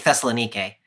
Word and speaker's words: Thessalonica: Amy, I just said the name of the city as Thessalonike, and I struggle with how you say that word. Like in Greek, Thessalonike. But how do you say that? How do you say that in a Bible-themed podcast when you're from Arkansas? my options are, so I think you say Thessalonica. I think you could say Thessalonica.